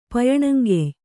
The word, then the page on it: ಪಯಣಂಗೆಯ್: Kannada